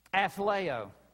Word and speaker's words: athleo: And the main root word, I'm going to pronounce it, you're going to hear the English word in it. Athleo. Athlete. Athletics.